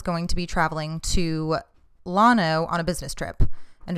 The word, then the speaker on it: Llano: yes, Llano is Spanish for plains and the double L is pronounced as a Y. However, this is from the fucking website. The common pronunciation of Llano by the locals is Llano.